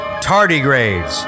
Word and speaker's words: tardigrades: And now it's been revealed that the mission was carrying a cargo of dehydrated microscopic life forms known as tardigrades. And if you don't know what a tardigrade is, it is a little microscopic shit that is indestructible. – And that's how you say it. – Tardigrades, yes.